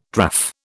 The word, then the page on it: draff: British English